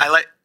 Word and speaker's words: illite: So there's Eyelight Clay, Bentonite Clay, which which is a green desert. – What was the first kind of clay? – Islite. – How do you spell it? – Islite. I-L-L-I-T-E.